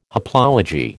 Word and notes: haplology: American English

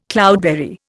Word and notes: cloudberry: Indian

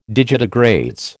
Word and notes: digitigrades: American English

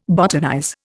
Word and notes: botanize: Indian

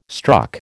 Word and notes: struck: American English

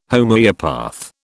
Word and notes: Homoeopath: British English

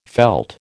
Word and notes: veld: American English

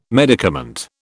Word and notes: medicament: British English

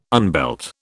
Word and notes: unbelt: British English

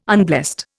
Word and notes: unblessed: Indian

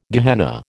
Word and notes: Gehenna: American English

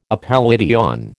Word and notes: appellation: American English

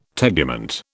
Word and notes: tegument: British English

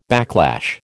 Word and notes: backlash: American English

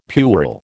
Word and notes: puerile: American English

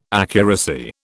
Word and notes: accuracy: British English

